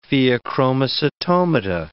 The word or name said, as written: pheochromocytomata